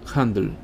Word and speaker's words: Handel: Pronto seguido por el italiano Bononcini, Handel se aclimató plenamente en Inglaterra, cambió su nombre a George Handel, sin la diéresis, y pronunciado Handel.